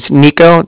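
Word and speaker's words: Niko: So my name's Nico. That's N-I-K-O. – Okay. – And then the Bank of Cardiff is spelled C-A-R, D as in dog, I, F-F as in Frank, so it's like car and then different.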